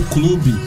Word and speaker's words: Club: A gente é, pronuncia clube, mas é um clube, pra ficar mais abrasileirado.